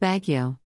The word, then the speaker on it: Baguio: He moved to Baguio, pronounced Baguio.